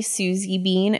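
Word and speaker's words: Suzy Bean: DJ Susie Bean, SUZYBEAN.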